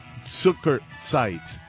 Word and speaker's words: Zuckerzeit: Klaus, my good Krautrock friend, reminding me that the way you pronounce that last CD is Zuckerzeit, kind of like a hard Z by Cluster.